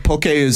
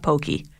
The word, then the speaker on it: poke: It's spelled P-O-K-E, but it's spelled like okay. Sorry, it sounds like okay, poke. – Right. Yeah. – Okay, that's how you got to say it, not pokey.